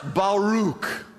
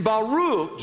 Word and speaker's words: Baruch: The first word, write this down, it's pronounced Baruch. It looks like Barak, like the name of our president, except there are two R's, and I think he has a C in that name. This is B-A-R-A-K, looks like Barak. But it's actually pronounced Baruch.